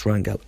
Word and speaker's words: Rangel: There's a guy called Sammy Rangel, I think it's pronounced Rangel, R-A-N-G-E-L, and he's done a fantastic TED talk, TEDx Danubia.